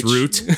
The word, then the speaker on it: Route: So I've been playing Kentucky Route Zero. – That's right. That's right. That's which... root. – Oh, such a good pronunciation.